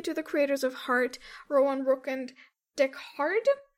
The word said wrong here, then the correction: Deckard: Is that how you say it? – Deckard? – Deckard.